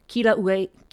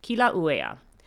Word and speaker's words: Kilauea: Pele is a goddess associated with volcanoes, particularly with the volcano called, let me see if I can say this right, Kilauea. There we go. I'm trying to make sure that I pronounce things as properly as I can. Kilauea.